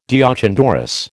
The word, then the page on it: deoch an doris: American English